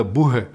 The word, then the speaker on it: boha: Et là, j'ai envie de vous faire découvrir Adrien Villeneuve, qui est un bouheur, qui joue de la bouhée. Ça s'écrit B-O-H-A.